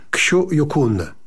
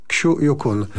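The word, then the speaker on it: Xu Yukun: Trzeba się nauczyć, proszę państwa, tego nazwiska. Ono się podobno wymawia Ksiu Jukun. Ksiu Yukun.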